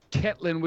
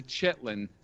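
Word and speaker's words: Ketlen: Up next, we had Ketlin, which is actually Chetlin. So it's K-E-T-L-E-N, but it's Chetlin Vieira.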